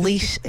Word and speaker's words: Leash: Sierra Leash at Gmail. You can email me. I will respond. – And leash exactly how it's spelled. – Sierra like the mountains, leash like a dog leash.